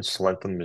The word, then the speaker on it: slenthem: a slenthem. – Yeah, from what I've seen, Slentham is the way that the English pronounce it.